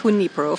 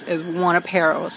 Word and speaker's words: Junipero: We are located at the corner of Fourth Street and Junipero correctly pronounced Junipero from Junipero Serra. Many people refer to it as, Juanapero. So if you're a Juanapero person, it is at Juanapero. We say Junipero.